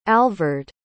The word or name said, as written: alvord